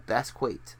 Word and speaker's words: Basquiat: He's in stuff I have not seen, but like he's in Westworld and Basquiat. That's how you say it.